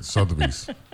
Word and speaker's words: Sotheby's: Where Sotheby's, that's how you pronounce it? – Sotheby's. I mean, if you're from New York, it's Sotheby's. – No, that's it. Sotheby's.